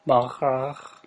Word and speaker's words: Baccarat: Baccarat.